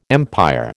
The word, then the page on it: empire: American English